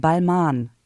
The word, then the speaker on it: Balmain: Weil ich meine, das mal gehört zu haben, das schreibt sich zwar mit M-A-I-N am Ende, aber ich glaube, es wird Balmain ausgesprochen.